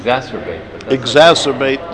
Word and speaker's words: exacerbate: E-X-C-E-R... To height. To make more. How do you pronounce it? – Exacerbate the problem.